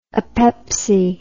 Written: apepsy

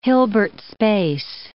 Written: Hilbert space

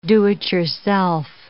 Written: do-it-yourself

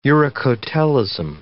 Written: uricotelism

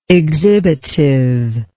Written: exhibitively

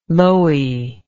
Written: Loewy

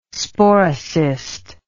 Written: sporocyst

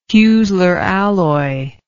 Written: heusler alloy

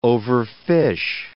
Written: overfish

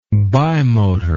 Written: bimotor

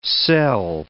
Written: sel

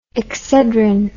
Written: excedrin

